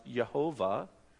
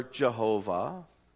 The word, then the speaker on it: Yahweh: They have said that this name, YHWH, is pronounced Yehovah or Jehovah or Yahweh.